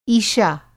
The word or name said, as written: ईशा